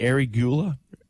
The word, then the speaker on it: arugula: and how do you say that? – Arugula.